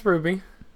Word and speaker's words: RWBY: Ruby is how you say it. – Yeah, it's Ruby. – What an idiot. Let's go. – Yeah, because Ruby is the name of the character, but also their team is Ruby, and it's spelled with a W. – Instead of a U.